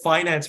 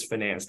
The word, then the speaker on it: finance: I can tell you that finance versus finance, that I think a lot of my American friends always used to give me... You know, I'm a CFA, right? So all of my CFA friends always said, you're saying it wrong, man.